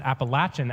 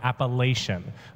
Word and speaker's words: Appalachian: I have now learned that they pronounce it Appalachian out there and not Appalachian. I've been trying really hard to train myself so I don't say the wrong thing.